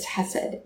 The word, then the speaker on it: hesed: So the Hebrew word is hesed, which I'm probably not saying that right. It has this k sound at the beginning.